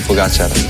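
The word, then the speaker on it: Pogačar: Juanma dice que es aguda, mientras que otros es drújula, así que aquí tenemos cómo se dice de verdad. – Tadej Pogacar.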